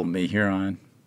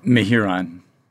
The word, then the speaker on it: Mehuron: And it's pretty simple, Mehuron. – So that's the, say it one more time. – Mehuron.